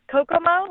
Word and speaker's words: Kokomo: So, you know, what's funny is we were talking about that Beach Boys song, you know, where I think it's called Kokomo, right? – Yeah.